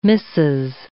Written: missis

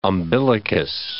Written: umbilicus

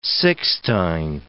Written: Sixtine

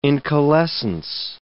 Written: incalescence

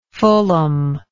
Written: fullam

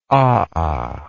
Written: aa

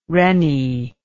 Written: rennie

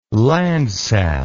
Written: Landsat

